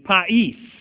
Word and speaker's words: pais: And a final one I'm going to give to you, there are a few others, but these are the main ones, is pais, and that's spelled P-A-I-S. P-A-I-S in the Greek.